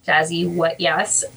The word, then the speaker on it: JazzyWatYes: So my at name is Jazzy What Yes, and let me spell that for you.